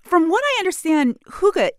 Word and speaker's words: hygge: It's a Danish word spelled H-Y-G-G-E. So how do you say it? Hygge? Is it hygge? Mike Viking, how's my pronunciation? – I think you passed the Danish test. It's pronounced hygge, so you're close enough.